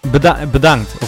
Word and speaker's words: bedankt: Bedankt of dankjewel. Dankjewel. – Bedankt? – Yeah, bedankt.